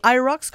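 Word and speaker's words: Hyrox: C'est IROX qu'on prononce ça? – Ouais.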